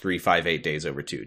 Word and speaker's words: 358/2 Days: Do you like that this video game is spelled 358 over 2 days, but it's pronounced 358 days over two?